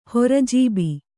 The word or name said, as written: ಹೊರಜೀಬಿ